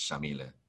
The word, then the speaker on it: Yamile: But her name is Yamile. You would pronounce it Yamil if you're from Puerto Rico like me. But if you're from Argentina, the way she pronounces it, it's Shamile. S-H sound with the Y, yeah.